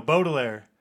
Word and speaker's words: Baudelaire: I don't know if I'm pronouncing that right. And Alaritos. What's that? – Baudelaire? – There you go, Baudelaire. – If it's a A-U... B-A-U-D? – Yes. – Okay, yeah. Baudelaire. Thank you, Lemony Snicket, for telling me how to pronounce that.